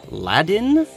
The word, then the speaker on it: Ladin: Moroder was born into a German-Italian family that also spoke the regional language of Ladin, if I'm pronouncing that correctly. It's L-A-D-I-N.